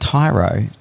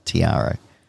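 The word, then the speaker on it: Tiaro: But it's pronounced Tyro, so the A is, you know, silent. And so we finally got told, because it's not Tiara.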